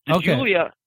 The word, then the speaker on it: Giulia: Giulia is just an amazing car.